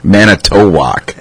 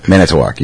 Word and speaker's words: Manitowoc: Manitowoc. – What's it? – Manitowoc. You gotta say it faster.